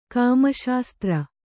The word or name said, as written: ಕಾಮಶಾಸ್ತ್ರ